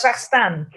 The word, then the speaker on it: Kazakhstan: They go through France, Belgium, Germany, the Czech Republic, Slovakia, Ukraine, Russia, Kazakhstan. I said that wrong. How do you pronounce Kazakhstan? – Kazakhstan, Mongolia, Canada, the U.S.